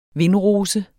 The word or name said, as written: vindrose